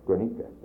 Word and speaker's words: Guernica: For instance, this, you call it, you give me the name of Guernavalca, because I've said it wrong all along. – Guernica, that Guernica painting.